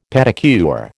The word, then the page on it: pedicure: American English